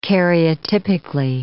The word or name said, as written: karyotypically